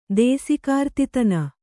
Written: ದೇಸಿಕಾರ್ತಿತನ

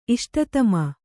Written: ಇಷ್ಟತಮ

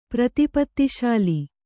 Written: ಪ್ರತಿಪತ್ತಿಶಾಲಿ

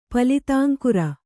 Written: ಪಲಿತಾಂಕುರ

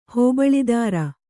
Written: ಹೋಬಳಿದಾರ

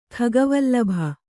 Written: ಖಗವಲ್ಲಭ

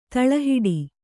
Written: ತಳಹಿಡಿ

